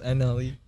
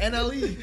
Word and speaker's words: NLE: I don't know how you pronounce the N-L-E, bro. – It's probably just N-L-E. – Yeah. Oh, it is. I'm pretty sure he says that in his song. He's like, N-L-E.